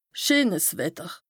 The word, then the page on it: scheenes Wetter: Bas Rhin; Strasbourg